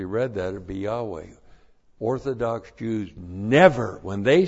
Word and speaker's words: Yahweh: Now, if you actually pronounce this in the Hebrew, you'll be pronounced Yahweh. You don't ever see that word. You have to know Hebrew to know that.